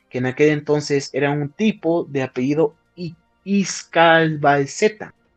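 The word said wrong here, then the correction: Icazbalceta: Iscalbalseta o Icasbalceta, no, Icasbalceta, perdón, así se, pronuncia, Icasbalceta,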